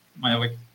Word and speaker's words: Mayabeque: Mayabeque o Mayabeque, ¿cómo se dice? Mayabeque. – Mayabeque, muy bien.